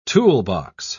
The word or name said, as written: toolbox